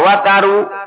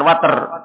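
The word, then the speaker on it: watar (وتر): Wal wataru apa? Al wataru bi fathah ini. Wawu sama taknya di fathah. Watar bukan water. Wawu sama taknya di fathah. Wawu dan taknya keduanya di fathah. Bacanya watar.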